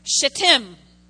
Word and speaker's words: shittim: Accent, I'm going to say the word, shittim. S-H-I-T-T-I-M.